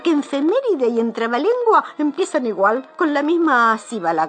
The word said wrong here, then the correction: sílaba: que le dicen. – No, se dice sílaba.